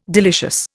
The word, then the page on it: delicious: Indian